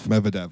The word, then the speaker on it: Medvedev: Sinner won versus Medvedev.